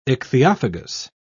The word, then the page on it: ichthyophagous: British English